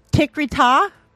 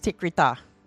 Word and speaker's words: Tikrit: Well, yeah, but it's actually, the phonetic pronunciation is Tikrita. There's actually a sound at the end of the T.